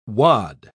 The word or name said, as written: wad